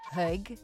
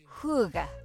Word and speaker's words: hygge: And I'm sure you've heard of hygge, H-Y-G-G-E, which is all the rage, which is how I thought you pronounced it, actually. But it turns out it's hygge, like H-O-O-G-A-H, hygge.